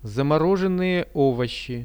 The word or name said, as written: zamorozhennyje ovoshi